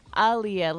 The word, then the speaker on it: Aaliyah: Aaliyah, like the singer.